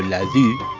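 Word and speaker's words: Ladoux: de apellido Ladue, así se pronuncia los que hablamos francés fluidamente, se supone que le ofreció sus servicios como doble agente.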